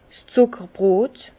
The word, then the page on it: 's Zuckerbrot: Bas Rhin; Herrlisheim